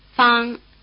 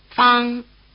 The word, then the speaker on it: Fang: Fang, Fang, Fang. – Notice that the vowel sound A is spelled with the letter A, as in Okinawa. Try repeating the surname after the speaker. – Fang, Fang, Fang.